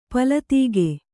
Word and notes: ಪೊಲ್ಲದುಗೈ: polladugai

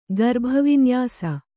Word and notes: ಗರ್ಭವಿನ್ಯಾಸ: garbha vinyāsa

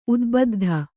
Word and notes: ಉದ್ಬುದ್ಧ: udbuddha

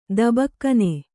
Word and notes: ದಬಕ್ಕನೆ: Kannada

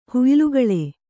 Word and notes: ಹುಯ್ಲುಗಳೆ: kannada

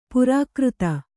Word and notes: ಪ್ರಾಕೃತ: Kannada

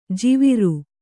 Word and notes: ಜುವ್ವಾರೆ: juvvāre

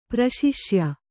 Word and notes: ಪ್ರಶಿಷ್ಯ: kannada

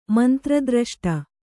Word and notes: ಮಂತ್ರದ್ರಷ್ಟ: Kannada

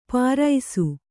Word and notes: ಪಾರೈಸು: pāraisu

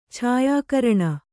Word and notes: ಛಾಯಾಕರಣ: kannada

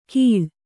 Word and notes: ಘೀಳ್: Kannada

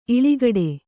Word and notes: ಇಳಿಗಡೆ: kannada

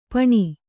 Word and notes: ಪೆಣ್ಣು: kannada

